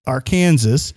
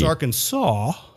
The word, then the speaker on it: Arkansas: So it's Arkansas, not S-A-W, but Arkansas. – Yeah. – So they have a state law saying, don't mispronounce our name. It's not Arkansas. – That is crazy. – It's not Arkansas. It's Arkansas. – That is ridiculous. – So, if you're in Arkansas, you do not want to put the emphasis on the wrong syllable, or you could wind up in the jail hoose.